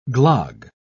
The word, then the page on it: glogg: British English